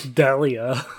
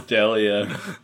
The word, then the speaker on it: D'Elia: Chris D'Elia. – Chris D'Elia. You know, like a deli.